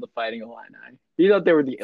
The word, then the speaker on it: Illini: You guys are the first people that I have ever heard them say Illini. – Illini, yeah.